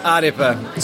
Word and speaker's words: Ārepa: Yeah, Ārepa.